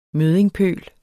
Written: møddingpøl

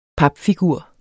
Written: papfigur

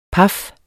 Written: paf

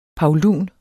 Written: PAULUNERNES

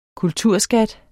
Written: kulturskat

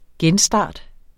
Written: genstart

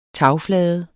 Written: tagflade

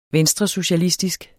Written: venstresocialistisk

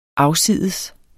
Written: afsides

